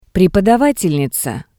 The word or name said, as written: prepodavatelniza